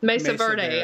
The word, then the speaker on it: Mesa Verde: She takes that Mercedes girl. I'm not saying that right. Mesa Verde. I said it wrong.